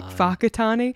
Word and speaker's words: Whakatane: Whakatane? It's with a WH, but it's pronounced with an F.